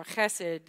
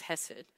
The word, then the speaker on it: hesed: That Hebrew word in this passage here is the Hebrew word hesed or chesed, depending on if you took Hebrew and you want to get fancy with that little pronunciation. But for the rest of us, it's just hesed.